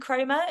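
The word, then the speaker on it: Comer: And I was watching this and then I remembered about, is it Jodie Cromer? Is that her name? Is that how you say it? – Mm-hmm. Jodie know Cromer.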